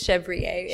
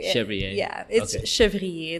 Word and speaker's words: Chevrier: It's Chevrier. Chevrier. It's very French.